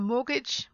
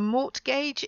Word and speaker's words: mortgage: but if you need to get a mortgage for your house, it's not a mortgage, it's a mortgage. So it's a silent T in the middle of this word as well, when you borrow money from the bank so that you can buy a house.